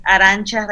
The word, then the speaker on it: Arantxa: Bueno, mi nombre se escribe un poco complicado, eh, es Arancha Rayeta Bajo Soto, se escribe A-R-A-N-T-X-A, Rayeta Bajo Soto.